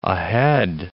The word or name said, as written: ahead